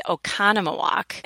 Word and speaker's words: Oconomowoc: But to pronounce the city is Oconomowoc.